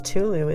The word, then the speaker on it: Cthulhu: He was widely known as an enthusiast of Lovecraft's Tulu Mythos Tales. Tulu is spelled C-T-H-U-L-H-U.